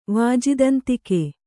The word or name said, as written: ವಾಜಿದಂತಿಕೆ